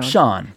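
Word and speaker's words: Siân: But it's pronounced just like the guy's name. – 그러네요. – Sean.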